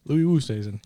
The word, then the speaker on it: Louis Oosthuizen: Louis Oosthuizen.